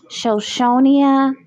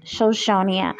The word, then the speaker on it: Shoshonia: And also check me out on TikTok at Shoshonia Shoshonia. I spell that for you. It's S-H-O-S-H-O-N-I-A.